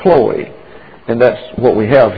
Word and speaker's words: Chloe: An omega is a long O, Chloe. But it's a short O, accented there.